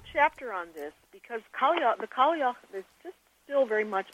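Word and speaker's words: Cailleach: Well, let's talk about the old woman goddesses who, I may mispronounce this, but I'll do it my best, was called Kalyakh. – Kalyakh, yeah, the Kalyakh. – Oh, Kalyakh, okay.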